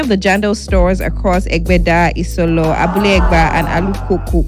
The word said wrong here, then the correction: Alakuko: Alukaku, if that's how you pronounce it. – Alakuko. – Alakuko. Thank you very much, Stipe.